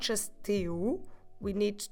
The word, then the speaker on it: teu: And your son, talking to a friend, have this in mind, is the possessive teu. Teu. Como se escreve teu? T-E-U. Teu. Quite similar to meu, if you remember.